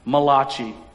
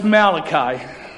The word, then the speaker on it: Malachi: Some people like to pronounce it Malachi, but it's Malachi. Malachi.